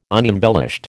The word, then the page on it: unembellished: American English